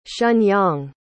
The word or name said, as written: shenyang